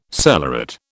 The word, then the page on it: cellaret: British English